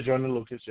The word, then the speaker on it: Joyner Lucas: Because Luca Joyner Lucas is did I say that right? Yeah, Joyner Lucas.